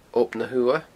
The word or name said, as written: Ob na h-Uamha